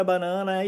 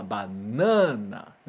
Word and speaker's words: banana: E a gente tem a mania de querer copiar essa pronúncia nas palavras no Nihongo, no japonês também. Então, quando a gente vê essa palavra, né? Banana, a gente quer falar banana, né? Mas presta atenção, os japoneses não falam assim.